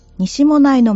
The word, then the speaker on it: 西馬音内: 西、馬、音、内と書いて、西馬音内と読みます。この盆踊りは、徳島の阿波踊り、岐阜の郡上踊りと合わせて、日本三大盆踊りと言われています。盆踊りは、西馬音内の町の中心部の大通りで行われます。